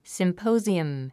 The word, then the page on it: symposium: simpóuziəm; スィンポォズィエム